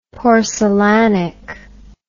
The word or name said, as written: porcelanic